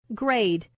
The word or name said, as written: grade